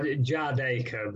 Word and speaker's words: Jardaker: Yeah, Jadeker, but Flatcap or Flatcapraps, you know, that's absolutely fine. Whatever people want to call me, to be honest. On the streaming platforms, I'm under Jadeker, so yeah.